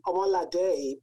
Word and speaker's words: Omolade: My name is Omalade, pronounced with a day at the, like an accent E at the end.